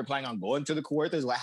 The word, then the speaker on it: Kawartha: So Charlie had the brilliant idea of going out to a cottage in Kawartha's, Ontario. Okay. Did I pronounce that right? – Kawartha. Okay, you got it.